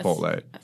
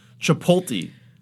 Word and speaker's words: Chipotle: Chipotle. – What is it with the T and the L that fucks them up so much? Chipotle.